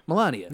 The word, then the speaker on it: Melania: Melania.